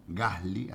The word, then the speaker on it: Gasly: Gasly, así se pronuncia, que saldrá primero y Colapinto séptimo.